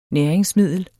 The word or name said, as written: næringsmiddel